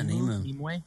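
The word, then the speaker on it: Nimue: From Stranger Things has been cast to play Hellboy, while Mia Jovovich to bring Nemu. Am I saying this right? – Yeah, Nemu.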